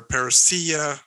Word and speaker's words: Parousia: The Parousia or Parousia is probably the proper name to pronounce it.